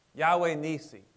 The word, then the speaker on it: Yahweh Nissi: Yahweh Nissi, spelled N-I-S-S-I. Yahweh is spelled Y-H-W-H, no vowels.